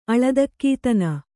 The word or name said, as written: ಅಳದಕ್ಕೀತನ